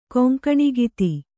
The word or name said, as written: ಕೊಂಕಣಿಗಿತಿ